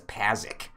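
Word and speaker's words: Pazaak: How I called it, Pazok, but again, how every character in the game refers to it differently, as Pazik.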